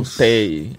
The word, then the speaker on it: Chantei: Não... Chantei. Ah, é, é. desculpa, é. e a gente estava discutindo Meu aqui. Você né? Tá em dia, Chantei. – A gente estava discutindo aqui a pronúncia, né? Chantai. – Chantai Away. – Ah, então é Chantei. Isso.